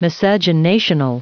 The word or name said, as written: miscegenational